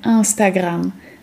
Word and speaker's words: Instagram: Zwróćcie też uwagę na wymowę nazwy tego popularnego portalu społecznościowego. Po francusku powiemy Instagram.